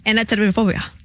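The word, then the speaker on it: anatidaephobia: A-N-A-T-I-D-A-E. – Anatibiophobia.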